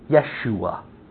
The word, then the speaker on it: Yeshua: Yahweh saves is the meaning of the name Jesus, Yeshua, the Hebrew pronunciation of Jesus.